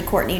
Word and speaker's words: Courtney: And I think my name is actually Courtney Droll.